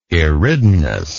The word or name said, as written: Eridanus